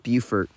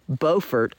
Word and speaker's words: Beaufort: And actually, it's funny because he now lives in Beaufort, North Carolina, which is spelled the same way, but pronounced differently. And it's funny, growing up, we always used to get those two confused, and because it's Beaufort County School District and Beaufort County School District.